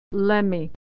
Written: lemme

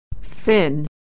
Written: fin

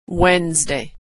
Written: Wednesday

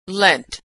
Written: lent

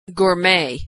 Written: gourmet

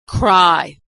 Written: cry

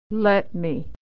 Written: let me

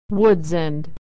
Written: woods and

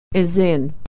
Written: is in

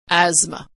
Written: asthma

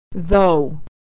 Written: though

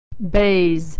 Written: bays

